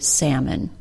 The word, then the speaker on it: salmon: Unfortunately, she did say the L sound perfectly and was then told by her boss that the L is silent in the word salmon.